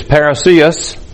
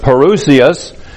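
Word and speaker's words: parousia: Notice again, verse 1, "Now concerning the coming," the word there, translated coming, is parousios, or parousios, it's pronounced variously, but parousios.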